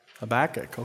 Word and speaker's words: Habakkuk: Do you say Habakkuk or Habakkuk? Habakkuk, okay. It just depends what syllable you put the emphasis on, I guess.